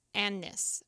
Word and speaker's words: Agnes: And apparently, even though it's written in the book as Agnes, A-G-N-E-S, it's actually pronounced Agnes or Agnes.